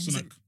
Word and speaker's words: Sunak: Sunak.